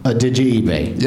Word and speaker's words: Adejuyigbe: And as far as I know, and from now on, I'm going to pronounce it Adejuyigbe. – Yep. Nailed it. – You which all is just the sentence, did you eBay? – Did you? – I'm glad it works. – It's perfect. That's the right pronunciation.